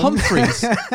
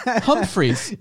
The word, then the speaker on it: Humphreys: Pronounces my name wrong. – Humphreys. Humphreys. – You said Humphrey.